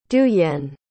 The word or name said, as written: duyen